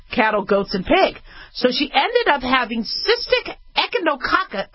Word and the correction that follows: echinococcosis: Oh, sorry. Okay, I got to pronounce this right. Echinococcosis, also known as hidatidosis.